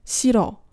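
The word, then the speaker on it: siro (싫어): 发音的规则呢是发这个左边这个 a 的这个收音，在跟后面的字连读的时候，如果是跟元音开头的字来连读的话，那么就直接只发第一个收音的音，第二个收音不发音。比如说在后面加这个 o 的时候，就会变成 siro，你就基本上听不到第二个收音的发音。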